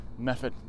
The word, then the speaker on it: Medford: And it's like they have a town not too far by which is called, well, it's spelled Medford. But if you listen to the locals, Method.